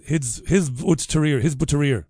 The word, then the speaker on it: Hizb ut-Tahrir: So they said jihad, these Hizb ut-Tahrir, that's how you say it.